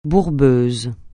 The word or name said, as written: bourbeux